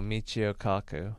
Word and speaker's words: Michio Kaku: Oh, Michio Kaku.